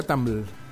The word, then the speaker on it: Tumblr: Es que se pronuncia Tumblr. Tumblr.